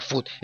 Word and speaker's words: Fudd: Me gusta, prefiero el apellido Fod o Food. Así que se pronuncia Food.